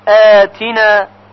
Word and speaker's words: آتنا: توجه دارید که در کلمه آتنا باید همزه تلفظ بشه، ربنا آتنا. اگر گفته بشه آتنا با عین غلط هست، ربنا آتنا.